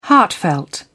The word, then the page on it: heartfelt: British English